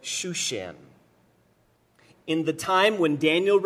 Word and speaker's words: Shushan: Shushan is the Hebrew pronunciation of this word. Susa is the Greek pronunciation of this city.